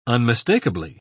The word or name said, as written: unmistakably